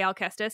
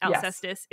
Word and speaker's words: Alcestis: So one, just to point of clarification, for my listeners, I'm going to say Alcestis because I always have, but I recognize that Alcestis is also a valid pronunciation in English.